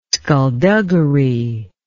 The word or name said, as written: sculduggery